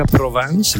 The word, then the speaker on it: Provence: Oh, si pronuncia Provence, ma noi italiani l'abbiamo adottata come Provenza e associamo Provenza a profumo, sì, la lavanda, ma anche di pane, di resina, di tempo lento.